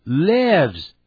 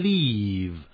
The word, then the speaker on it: live: 他住在这里，跟着写，He lives here。 He lives here，因为主语是 he，所以我们的 live 就加个 s。 He。 Lives here，记住口不要念成 leave，不要念成 li，要念 live。